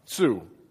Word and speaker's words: Tzu: For those that don't know what it is, it's an ancient Chinese military treatise that is attributed to a man named Sun Chu. Anybody know how to pronounce that? Tzu. Okay, good.